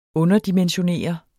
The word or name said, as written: underdimensionere